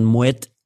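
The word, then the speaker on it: Moët: Also du sagst dann Moete et Chandon so ist der, die richtige Aussprache, wenn du das, den ganzen Begriff, und wenn du den Rest dann weglässt, würdest du es trotzdem so aussprechen, als ob der Rest dann noch käme, ja, und deswegen würde ich immer Moet sagen.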